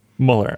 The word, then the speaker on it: Mueller: It doesn't really matter, but on the podcast they pronounce it Mueller. – Mueller. Okay, I'll say Mueller then to respect them, which I don't need to do.